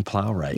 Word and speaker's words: Plowright: How do you say her last name? Plowright? – Joan Plowright. Yeah.